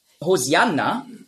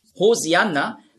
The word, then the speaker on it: Hosianna: Hosianna ist einfach die griechische Aussprache von Hoshi'anna. Hosianna, das ist die griechische Aussprache. Weil die Griechen, die haben im Alphabet kein Sch-Laut. Nur Z.